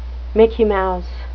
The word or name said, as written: mickey mouse